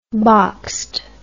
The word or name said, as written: boxed